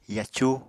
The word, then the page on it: yacu: Apatani